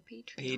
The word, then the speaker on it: Patreon: O Patreon, ¿cómo se dice? – Patreon. Patreon, pues. Eso es en inglés, mi gente, pero eh, Patreon.